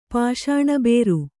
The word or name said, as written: ಪಾಷಾಣಬೇರು